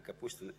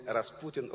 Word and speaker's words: Kapustin: Kapustin. I understand that sometimes some people call me Rasputin or like this. But it's not right. So, Kapustin.